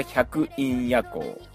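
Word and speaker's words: 百音夜行: タイトルが百陰夜行、百に音と書いて百鬼夜行の夜行で百陰夜行ですね。まあ、あの音をオンじゃなくてインと読ませたのは、あの夜行百鬼夜行の中に百多くの妖怪たちがうごめいているっていう意味で、イン百陰夜行っていうね、英語のインという、意味でも、まあ、取れるようにしました。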